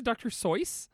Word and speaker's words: Seuss: Did he pronounce it Dr. Soice? – I thought so. I think that's always the Dr. Seuss Institute is like, the first thing they say is like, oh, actually he pronounced it Dr. Seuss.